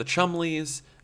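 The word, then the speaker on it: Cholmondeley: Is that Chumley is spelled Cholmondeleys. So if I were to just try to pronounce it, I would say Cholmondeleys. – And they'd say, excuse me, it's Chumleys.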